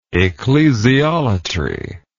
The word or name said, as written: ecclesiolatry